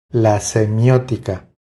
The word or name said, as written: La semiotica